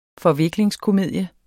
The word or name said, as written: forviklingskomedie